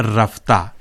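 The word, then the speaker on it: رفته: یہاں رفتہ چھوٹی ہے کے ساتھ استعمال ہوا ہے. جیسے کہ ہم بتا چکے ہیں کہ چھوٹی ہے کا تلفظ فارسی میں بڑی یہ کی طرح کیا جاتا ہے, اور چھوٹی ہے یہاں ہائے غیر ملفوظ کہلاتی ہے, یعنی جس کا تلفظ نہ کیا جائے.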